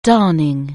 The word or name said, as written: darning